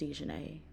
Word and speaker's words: Dijonnaisa: And it is pronounced Dijonay.